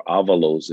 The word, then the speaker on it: Avalos: For example, Boise State's head coach, Andy Avalos, or Avalos is pronounced.